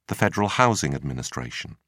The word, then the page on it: federal housing administration: British English